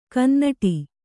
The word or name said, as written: ಕನ್ನುಡಿ